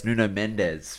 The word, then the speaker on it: Nuno Mendes: pronounce his name in correct Portuguese, and it just kind of sounds funny. Nuno Mendes has signed for PSG. That's Nuno Mendes for any not Portuguese people out there.